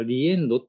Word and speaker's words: RE-END: えっと、えー、塚田ありなさんと高橋美玲さんという 2 人の方が編集した、えー、これはリエンドって読むんだと思いますけど、再びの RE のリ、 The End